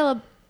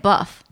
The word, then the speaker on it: LaBeouf: Yeah, I say LaBeouf. – Me too. Yeah. – Because that's how you would pronounce O-E-U in français.